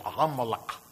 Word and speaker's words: Amalek: The first thing is that the word Amalek is how you say it in Hebrew, Amalek.